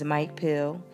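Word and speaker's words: Mike Peele: So it's this guy, his name is Mike Pill. It's spelled MikePeele.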